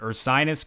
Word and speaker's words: Ursinus: Is it Ursinus College Escape Velocity Dance Company? – Yep, and you're the only one who's ever pronounced that right.